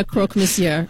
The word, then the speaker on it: croque monsieur: I had my espresso martini, my secondhand smoke, and a croque monsieur, and I was good to go.